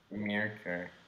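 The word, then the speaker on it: Myrkur: Mercure.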